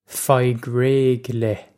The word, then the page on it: faigh réidh le: feyeg rayg leh; Irish